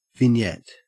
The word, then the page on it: vignette: Australian English